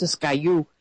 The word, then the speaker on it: Siskiyou: I think it's pronounced Siskiyou County.